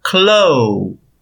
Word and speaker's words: clothes: C-L-O-T-H-E-S. A primeira parte ficou tranquilo. Close. Mas a segunda, close. Essa pronúncia ela é muito fácil. A escrita é um pouco complicada, mas a pronúncia é super simples.